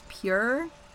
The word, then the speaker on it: Pjur: But one of the many things I learned is that the silicone-based lube that I think is pronounced Pure, it's P-J-U-R. – Yes, Pure.